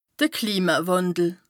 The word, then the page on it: de Klimawàndel: Bas-Rhin; Strasbourg